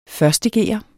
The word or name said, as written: 1½